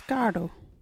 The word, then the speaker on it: gaardu: Another wiggly word is gardo. What is a gardo? Gardo is playing cards. You can use gardo in this sentence. All the woman this one, gardo. Gardo is spelt G-A-A-R-D-U. Can you say gardo?